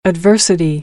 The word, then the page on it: adversity: American English